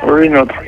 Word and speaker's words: Renault: We call it Renault in Nigeria. Renault. What is the correct pronunciation? – That's Renault. Renault. – Huh? – Renault. Renault. – Well, I told you it's a French brand. And you know, you should know ordinarily that that T will be silent.